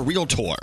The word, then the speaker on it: realtor: I like how you said, I'm your realtor. – That's how you say it.